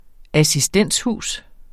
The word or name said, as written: assistenshus